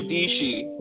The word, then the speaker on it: Dishi: this girl Sarah Dishi rhymes with peachy.